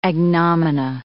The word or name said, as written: agnomina